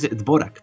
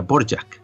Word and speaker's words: Dvořák: O Antonín Dvořák, en español es Dvořák, pero por lo visto se pronuncia Dvořák, ¿vale?